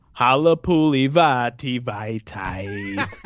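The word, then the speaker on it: Halapoulivaati Vaitai: Obviously, he was an Eagles guy before, but if you say his name to play that funky music, white boy, you'll never not get it right. – Here we go. – So it goes, Halapuli Vati Vitae.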